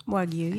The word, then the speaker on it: Wagyu: Am I saying that right? Wagyu? – Wagyu, yeah.